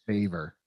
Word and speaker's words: Favre: Favre. – Buckhead Brett. Okay, what a great nickname. – I thought it was Favre, like the bean. – Yes. the fava bean, Brett Fava Bean, but I'm going to take him.